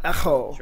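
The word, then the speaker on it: echo (ἔχω): Hold firm, which is help me with my pronunciation now. Echo. E-C-H-O, echo. Is that how you say it? – Yeah, so epsilon key omega.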